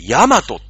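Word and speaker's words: 大和: あれは、もう大きな和と書いて、ヤマトって読むしかないんですよ。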